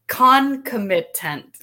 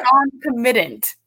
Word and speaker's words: concomitant: Joy is the concomit Concomitant. – Ah, I can't. – you got it. You got it. Concomitant.